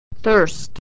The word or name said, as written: thirst